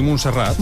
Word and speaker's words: Montserrat: ¿Lo he dicho bien? ¿Montserrat? – Sí, Montserrat. – Montserrat, no he dicho bien. Montserrat. – Sí, sí. Montserrat. Y hablando del Papa, precisamente se ha juntado con Bad Bunny, se reunieron el lunes en privado en el Santiago Bernabéu.